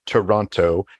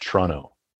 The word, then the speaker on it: Toronto: she pronounces it toronto canadians do not pronounce toronto with the second t they pronounce it toronto Terrible.